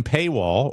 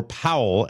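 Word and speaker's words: Pawol: Her name is Jen Paywall, or Powell as it's pronounced. It's P-A-W-O-L.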